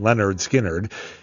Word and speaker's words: Lynyrd Skynyrd: Right now, here on Afternoons Live, in 1973, Lynyrd Skynyrd released their debut album, which they have is pronounced Lynyrd Skynyrd.